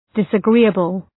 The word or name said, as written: disagreeable